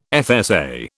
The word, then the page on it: FSA: British English